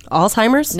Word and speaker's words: Alzheimer's: Alzheimer's? – Now that I'm learning how to say it. – Good job.